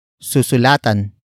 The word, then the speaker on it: susulatan: Word for word, it's susulatan kita.